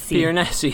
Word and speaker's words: Piranesi: Did we ever settle on a pronunciation? Piranesi? – Piranesi. – Yeah, for no reason in particular, a very Italian sound, a little Italian twang to that.